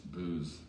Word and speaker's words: Booz: But his name is Ron Booz. Booz. It's spelled booze.